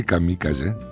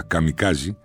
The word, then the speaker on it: καμικάζε: Η λέξη καμίκαζε, εσφαλμένα κατά την αγγλική προφορά καμικάζει, είναι ιαπωνική και σημαίνει θεϊκός άνεμος.